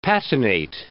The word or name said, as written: patinate